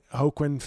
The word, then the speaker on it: Joaquin: The J, I think, is pronounced like an H. – So, Joaquin Phoenix.